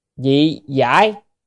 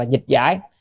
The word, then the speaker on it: dị giải: Tịnh Độ Thánh Hiền Lục Dị Giải, chữ này đọc là dị giải cho nên, hoặc là dịch giải, tức là Tịnh Độ Thánh Hiền Lục.